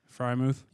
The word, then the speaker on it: Freiermuth: Freiermuth. – Yep, Freiermuth.